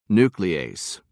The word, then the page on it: nuclease: American English